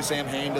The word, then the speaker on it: Samhain: Your history with Danzig, your history with Sam Hain. Is that how you pronounce it, or is it Sam Wow? – Samhain. Yeah, but Nobody says that, so we just say Sam Hain.